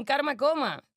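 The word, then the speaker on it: Karmacoma: Ha hecho videoclips como Karma Police de Radiohead, que es increíble, y su debut como director fue con Massive Attack en Karma Coma, ¿se dice así? – Sí.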